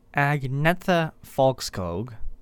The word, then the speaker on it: Agnetha Fältskog: Agnetha Fältskog,